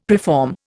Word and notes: preform: Indian

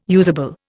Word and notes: usable: Indian

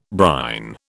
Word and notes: brine: British English

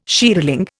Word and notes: shearling: Indian

